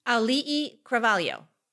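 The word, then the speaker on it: Auli'i Cravalho: Ali'i Cravalho. – See? Like, it's a fun name to say. – Ali'i. It's yeah. so good. – Ali'i Cravalho.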